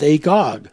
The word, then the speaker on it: Agag: and there's words like Agag, A-G-A-G, but the Septuagint actually pronounces it as Agog, so that just a simple letter can change a person from not knowing the connection of an ancient thing.